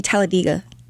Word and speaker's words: Talladega: Actually, people in Alabama, it's actually Talladega. – Oh, yes. That's how you say it, Talladega.